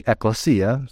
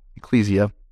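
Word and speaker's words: ekklesia (ἐκκλησία): ekklesia, sometimes pronounced ecclesia, common nomenclature.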